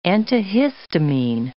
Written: antihistamine

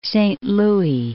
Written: Saint Louis, Lake

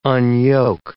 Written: unyoke

